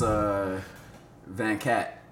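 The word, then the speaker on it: vingt-quatre: I know in French it's Van Cat.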